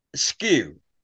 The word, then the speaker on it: Sque: Skew, English people, when they see it written, can't pronounce it. And I was plagued, not bullied, but teased and whatnot when I was at Skew.